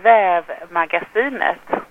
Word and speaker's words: Vävmagasinet: In Swedish, you say Vävmagasinet, but we have also this English name, the Scandinavian Weaving Magazine.